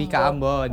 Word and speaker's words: Bika Ambon: Bika Ambon.